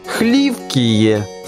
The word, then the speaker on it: Хливкие: «Хливкие» следует произносить с ударением на первом слоге, «Хрюкотали» — на третьем, а «Зелюки» — на последнем.